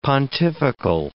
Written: pontifical

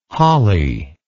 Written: Holley

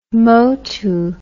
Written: motu

